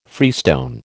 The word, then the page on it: freestone: American English